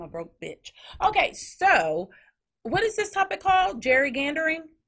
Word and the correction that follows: Gerrymandering: Gerrymandering. – Gerrymandering.